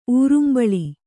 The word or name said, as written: ಊರುಂಬಳಿ